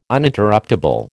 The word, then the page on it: Uninterruptable: American English